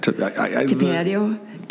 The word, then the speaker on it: Tapatio: Tapatio? T-A-P-A-T-I-O.